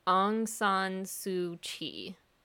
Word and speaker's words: Aung San Suu Kyi: So it's spelled AungSanSuKyi. But I believe the correct pronunciation is Aung San Suu Kyi.